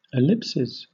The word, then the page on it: ellipses: British English